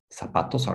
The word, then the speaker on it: zapatos: Zapatos is spelled with a Z. Okay, in Spain, in España, they say za, like a TH sound. Zapatos. But you know, in Latin America, we just the Z is pronounced like an S, sa. So a lot of people say zapatos, z, but it's not zapatos; it's sa, like an S, zapatos.